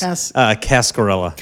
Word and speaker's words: Cascarilla: How do we say Cascardelli? – That was not close. Cascarilla.